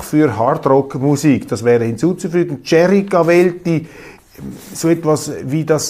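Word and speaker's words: Gieri: Der Mann heiße nicht Gary, sondern Jerry Cavelti.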